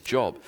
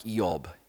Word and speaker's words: Job: It's actually, we in English, we actually say that wrong. If we were to say it the way it was probably said in the day, it sounds something like Eob, Eob. Because the J has a Y sound, so it's Eob.